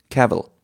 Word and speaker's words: Cavill: Es wird ausgesprochen wie Travel. Cavill. – Ja. – Travel. Cavill. Genau.